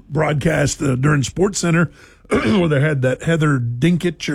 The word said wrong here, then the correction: Dinich: or however you pronounce her last name. – Dinich and Paul Feinbaum were down there.